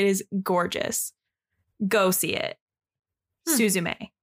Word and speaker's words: Suzume: It's called Suzume. That's how it's pronounced.